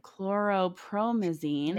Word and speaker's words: chlorpromazine: Chlorpromazine.